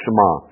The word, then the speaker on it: Shema: The Jewish people have something that's called the Shema. I believe that's the proper pronunciation, the Shema.